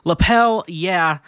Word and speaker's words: Lapel Yeah: Our first sponsor is, ooh, hit the mic a little bit, Lapel Yeah. Rhymes with hell yeah, as in give me a lapel.